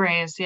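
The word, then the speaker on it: Briseis: like powerful emotion evoking part was when Briasis, I think that's how you say it. – Briasis, yeah.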